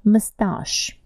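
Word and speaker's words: moustache: Mustache – усы. Слово французское произносится на французский манер. Mustache. How do you spell it? M-O-U-S-T-A-C-H-E. M-O-U-S-T-A-C-H-E.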